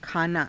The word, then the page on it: khaanaa: Hindi